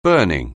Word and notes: burning: anglais

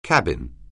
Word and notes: cabin: anglais

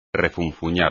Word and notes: refunfuñar: espagnol

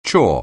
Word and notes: chore: anglais

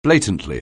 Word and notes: blatantly: anglais

